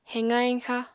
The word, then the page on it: heqa-heñxa: apatani